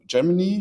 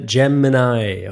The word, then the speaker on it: Gemini: Google Bard heißt jetzt nicht mehr Google Bard, sondern Gemini. Ich habe es richtig ausgesprochen, stimmt? – Nein, hast du nicht. Weißt du aber mittlerweile selber, wir haben mehrfach drüber geredet, das ist das schöne Gemini und da Gemini.